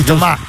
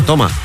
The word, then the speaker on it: Tomà: E da lì deriva il budello di Toma. – Di Tomà. – Ah, Tomà, l'accento. – L'accento è importante.